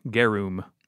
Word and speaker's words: garum: I got two pronunciations, garum and garum, but I think the biggest thing is that U is an oo sound, garum.